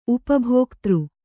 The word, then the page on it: ಉಪಭೋಕ್ತೃ: upa bhōktř